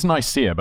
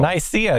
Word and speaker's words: Nicaea: So the first council of Nicae. – I think it's Nicaea, but I'm not sure. – Nicaea, yes. I believe that's great. That sounds right.